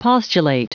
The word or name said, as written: postulate